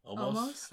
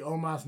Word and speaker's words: Omos: Okay, so you and fucking Daly say it wrong. – Omos? – I say almost. – Almost? – I'm pretty sure I've heard AJ say almost when he got introduced. I know they say Omos now.